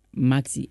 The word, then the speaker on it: Maxzy: Maxi, M-A-X-Z-Y. – M-A-X-Z-Y.